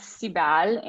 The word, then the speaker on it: Sibel: And there's this great kind of like throwaway line that your narrator, Sibyl, it's pronounced Sibyl? – It's Sibel in Turkish, but it's a great, it was the perfect name for her because she's doom oriented.